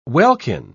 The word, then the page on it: welkin: British English